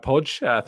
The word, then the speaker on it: Podge: Pronounce Podge, says Podge. Thank you for clearing that up.